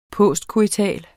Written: postcoital

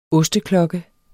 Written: osteklokke

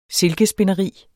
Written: silkespinderi

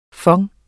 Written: FONDETS

